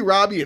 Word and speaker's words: Robbie: It's spelled with two B's. Yeah, I don't know why. – What a strange thing. I've said Robie too. – I think it's because of Margot. You know what I mean? – Like, yeah, you want to say Margot, Robie. You want to like continue this alliteration of the long O.